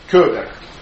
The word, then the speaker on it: Кёбер: Кёбер. Вторая буква «Ё», «Ё», да? Ударение на «Ё».